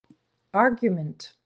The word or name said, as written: argument